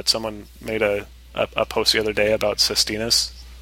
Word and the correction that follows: sestina: or maybe it's pronounced differently and I am showing my lack of familiarity again, but how else would you pronounce it? Sestina? – Sestina. – It's probably Sestina, yeah.